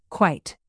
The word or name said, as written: quite